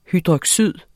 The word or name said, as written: hydroxyd